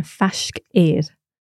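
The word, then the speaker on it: faisg air: So to say near in Gaelic, we use the expression fashk eir. – Fashk eir. Eir. That's right. Yeah. – What's the sound at the end? – So it's two words, fashk eir, and the second word is A-I-R.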